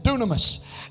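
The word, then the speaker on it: dynamis: Now it's D-Y-N-A-M-I-S, but you pronounce it dunamis.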